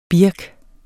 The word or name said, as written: birk